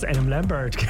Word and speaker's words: Adam Lambert: Adam Lambert. – Du hast Adam Lambert.